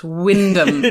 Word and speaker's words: Wymondham: I mean, you live right next to Wymondham, pronounced Wyndham.